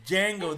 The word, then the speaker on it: Django: It's Django. The D is silent. In front of it.